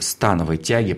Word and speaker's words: становой: мастера спорта по пауэрлифтингу и становой тяге, потому что именно так правильно ударение ставить, да? – Все правильно.